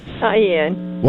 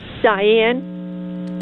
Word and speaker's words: Dian: Diane. – What is it? – Diane. – Can spell it for me. – D-I-A-N.